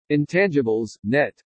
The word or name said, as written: INTANGIBLES NET